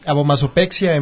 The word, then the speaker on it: abomasopexia: Y a ver, ¿cómo se pronuncia esto? El tema tuyo. – Se llama abomasopexia en bovinos.